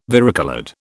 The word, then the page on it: varicoloured: British English